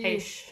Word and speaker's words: Heche: Anne Hetchy? – Heche. Heche? Yeah. Okay. – Spelled H E C H E, so I'm not good at reading um, okay.